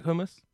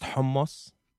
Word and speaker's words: hummus: You like hummus? – Yeah. – You know it's pronounced hummus in Arabic? You say hummus.